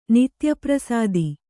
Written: ನಿತ್ಯಪ್ರಸಾದಿ

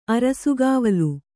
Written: ಅರಸುಗಾವಲು

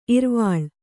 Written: ಇರ್ವಲ

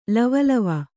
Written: ಲವಲವ

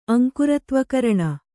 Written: ಅಂಕುರತ್ವಕರಣ

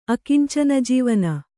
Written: ಅಕಿಂಚನಜೀವನ

